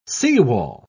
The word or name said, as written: seawall